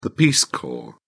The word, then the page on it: peace corps: British English